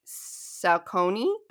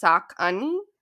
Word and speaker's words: Saucony: The running shoes and apparel, I always used to say Saucony. That's what I thought it was, but it's Saucony.